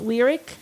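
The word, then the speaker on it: Lyriq: But you've already said it's Lyric. You've already said it's Celestic. So if you do the ick is how you pronounce it ending, it's going to be the Escalade ick.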